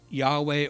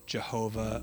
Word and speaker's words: Yahweh: You can add the E, the A, A-E-I-O-U. No, not sometimes Y, not in this one. There are no vowels, but it is pronounced Yahweh, or sometimes Jehovah, or Yehovah. I don't know. Most commonly, we hear it as Jehovah or Yahweh.